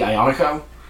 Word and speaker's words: Aglianico: Like Aglianico? – I do like Aglianico. Um, the accepted pronunciation for that grape in Arizona seems to be Ionico.